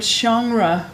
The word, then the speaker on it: genre: Someone gave us a request on Instagram asking me to say the word genre more. And now I don't know why I'm saying that wrong. Genre? – No. – It's not wrong. – It's correct.